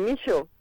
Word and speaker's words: Michot: Muy fácil, Micho.